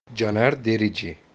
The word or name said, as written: Caner Derici